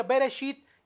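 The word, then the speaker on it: Bereshit (בראשית): Si se dan cuenta, ahí en Génesis, que en, hebreo se pronuncia Bereshit, así significa el nombre, Bereshit, dice así en el verso 1.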